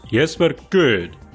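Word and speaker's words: Jesper Kyd: Apparently it is Jesper Kidd. Jesper, definitely. I saw him talking about it on Twitter, but I did not expect Kidd. Jesper Kidd!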